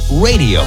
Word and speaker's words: Raydio: The second is recorded by a four-man group from Los Angeles called Radio. That's spelled R-A-Y-D-I-O.